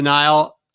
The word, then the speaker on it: Nihil: I will tell you, thank you for the guy that corrected my uh, pronunciation very thoroughly on the Nihil. Uh, it is the Nihil.